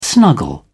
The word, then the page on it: snuggle: British English